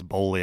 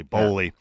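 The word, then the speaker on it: Boehly: I think it's Bowley. I think that's how you pronounce it, not Bailey. Bowley.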